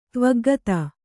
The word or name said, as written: ತ್ವಗ್ಗತ